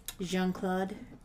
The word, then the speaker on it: Jean-Claude: Jean-Claude Van Damme. – Oh, really? – Yeah. – You mean Jean? – Jean-Claude. – It's a soft J, not a hard J.